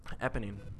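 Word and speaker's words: Éponine: Eponine. Yeah. It sounds kind of like Ebony. But Eponine.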